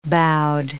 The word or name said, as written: boughed